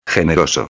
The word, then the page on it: generoso: espanhol